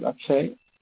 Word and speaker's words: Akshay: My first name is Akshay. – Spell it, please. – A-K-S-H-A-Y.